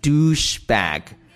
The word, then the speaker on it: douchebag: 这个词儿呢是我们今天讲的重点，叫 douchebag，注意发音，不是 douchebag 或者是 douchebag，是 douchebag，